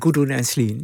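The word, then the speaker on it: Gudrun Ensslin: Gudrun, zeg ik het goed? – Ja, Gudrun Enslin.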